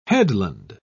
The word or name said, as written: headland